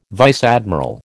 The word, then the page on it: vice admiral: American English